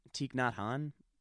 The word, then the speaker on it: Thich Nhat Hanh: Like, but I think Thich Nhat Hanh, or one of those spiritual dudes, is just like... – Say his name again? – Thich Nhat Hanh.